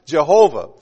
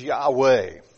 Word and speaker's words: Yahweh: The old scholars believe that the Hebrew word was pronounced Jehovah. But more modern scholars believe that the better pronunciation, the more accurate pronunciation, is Yahweh.